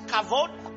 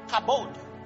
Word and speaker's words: kavod: The Hebrew word for glory, it's spelled two ways. It's kavod, K-A-V-O-D, or kabod. Kabod.